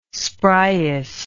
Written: spriest

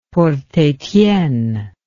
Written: Port Etienne

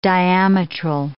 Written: diametral

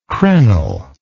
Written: crenel

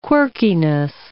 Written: quirkiness

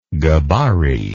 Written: gbari

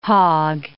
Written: hog

